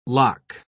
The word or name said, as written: loch